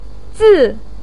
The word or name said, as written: zi4